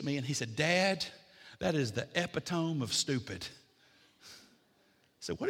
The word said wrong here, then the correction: epitome: I said, I think, son, you mean epitome. You put the emphasis on the wrong syllable.